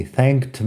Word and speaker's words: thanked: He thanked me for my time and left. Puedes escuchar la pronunciación thanked. K-t. Tiene la K y la T al final. La ED se pronuncia como T en este caso.